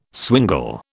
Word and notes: swingle: British English

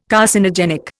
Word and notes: Carcinogenic: Indian

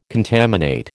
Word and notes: contaminate: American English

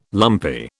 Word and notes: lumpy: British English

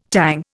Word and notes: Tang: Indian

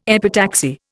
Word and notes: epitaxy: Indian

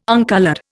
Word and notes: uncoloured: Indian

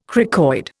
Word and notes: cricoid: Indian

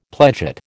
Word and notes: pledget: American English

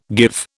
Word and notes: give: British English

